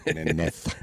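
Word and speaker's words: Jiménez: Jim Furyk, he's 4 off the lead, as is the guy that we all love to say his name, Miguel Angel Jimenez. – Jimenez. – That's right. Really, not Z at the end, I know.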